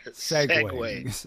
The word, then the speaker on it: segue: Segue. Is that the right pronunciation? – Segway.